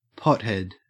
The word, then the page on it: pothead: Australian English